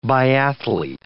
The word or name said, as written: biathlete